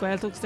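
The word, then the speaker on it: Gaeltacht: And then we've had a few pop-up Gaeltachs there, too, so people are coming in. – Pop-up Gaeltach. – Mm-hmm. – Did I say it right? – Yeah, spot on.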